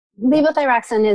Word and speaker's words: Levothyroxine: I can't pronounce it. Levothyroxine, is that right? – Levothyroxine is Synthroid.